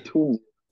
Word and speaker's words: Tunisia: Tunisia. – Speed says Tunisia.